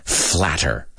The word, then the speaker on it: flatter: Flatter. Okay? F-L-A-T-T-E-R. As you guys know, Americans, we a lot of times pronounce that word flatter, those T's as if they were a D, right? A D.